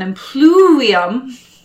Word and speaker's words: impluvium: So the atrium would have been open and filled with light, and it would have an impluvium. That's how you say it in Latin.